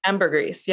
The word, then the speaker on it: ambergris: Ambergris? How do you say this? – Ambergris, yeah.